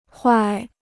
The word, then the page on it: 坏: huài